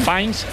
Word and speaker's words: Fiennes: ¿A que no sabéis el cargo que interpreta exactamente Ralph Fiennes? ¿En? Fines, en Fines. Conclave.